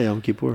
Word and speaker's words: Yom Kippur: Yom Kippur, is that how you pronounce it? – Yeah, Yom Kippur.